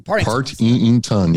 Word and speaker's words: Partington: Partington. – Yeah, it's three syllables.